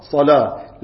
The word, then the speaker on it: صلاة: صل و هكذا تكتب لكن تلفظ صلاة. ليه؟ لأنها تفخم اللام فيها في بعض القراءات وصلاة صاد لام مفخمة، نحن قراءتنا بالتخفيف وبالترقيق. نعم.